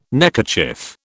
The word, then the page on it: neckerchief: British English